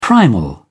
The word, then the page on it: primal: British English